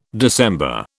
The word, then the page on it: December: British English